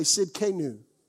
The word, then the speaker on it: Tsidkenu: Everybody say Tsidkenu. The T is silent.